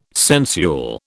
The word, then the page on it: sensual: British English